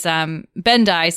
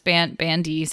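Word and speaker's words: Bendii: And I also wanted to mention that, the Vulcan Alzheimer's is Bendai syndrome, sometimes pronounced Bandi syndrome.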